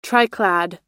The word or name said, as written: triclad